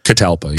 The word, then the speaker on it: catalpa: Tecalpa tree, is that how you pronounce it? – Catalpa, yeah. Catalpa, okay. And when she goes up her tree, if I may say, she'll have a minute.